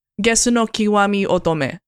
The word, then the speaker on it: Gesu no Kiwami Otome: Gesu no Kiwami Otome.